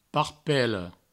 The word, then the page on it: parpalhon: parpaioun